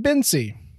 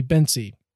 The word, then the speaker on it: Bence: And number two, you pronounce his name Bincy. Both of those took me completely by surprise. Number one, B-E-N-C-E. One would think that would be Bence. It's apparently Bency.